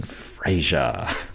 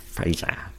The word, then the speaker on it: Fraser: That the most generous one has given it 2.3 out of 4 on average for the filmography of Brendan Fraser. I said his name wrong. That's how much we love him here. Brendan Fraser. – Fraser.